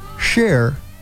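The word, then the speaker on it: Cher: Share is C-H. That's Share in the Shoop Shoop song.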